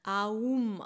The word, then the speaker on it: Om: Und tatsächlich wird das Om auch eigentlich Aum ausgesprochen. Also quasi dieses A, wir machen den Mund auf, wir beginnen, das Universum beginnt, wir beginnen unser Leben mit einem A, mit einem Schrei.